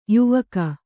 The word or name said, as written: ಯಾವಾಕೆ